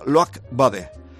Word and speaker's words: Loïc Badé: Primer fichaje, llegaba ayer, un tal, se escribe Loïc Badé, me decían los que hablan francés que se pronuncia Loïc Badé,